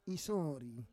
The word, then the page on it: isori: Roon